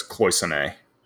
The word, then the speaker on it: cloisonné: I've always heard it as cloisonné. – It's probably the anglicized, correct way to say it.